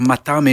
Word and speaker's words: Mathame: Le remix est signé par Matam et nos, invités qui seront en interview tout à l'heure, puisqu'on aura Matteo, la moitié de et puisque Matame, qui s'écrit avec un H entre Matt et Amé, veut dire tout simplement Matteo et Amedeo, puisqu'ils viennent euh, d'Italie.